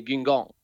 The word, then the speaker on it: Guingamp: Spelar i Guingamp, om jag uttalar det rätt. – Ja, det får du fan 4+ för.